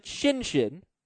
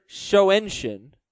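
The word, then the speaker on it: Schoenchen: We pronounce it Shinshin. – Yeah, I've learned. – I've also heard Shoenshin, but these people are wrong.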